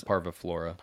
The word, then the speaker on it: parviflora: How do you pronounce Parviflora.